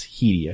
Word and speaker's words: Headey: It's not like Headley, it's Headey, I think.